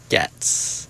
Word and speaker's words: gecs: I heard it's 100 Getz. – Getz? – The pronunciation, theoretically. It's spelled G-E-C-S, do with that what you will.